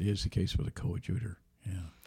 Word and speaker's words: coadjutor: the coadjutor. – Coadjutor. – Yes.